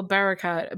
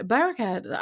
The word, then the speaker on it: Barakat: Like, oh my god, I just saw Jack Barak from Old Time Low. Oh, Barakat. Barakat. I never know how to say his last name.